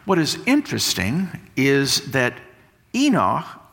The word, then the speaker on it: Enoch: In Hebrew, it's k at the end. It's Enoch. We're going to get that hard C on the end.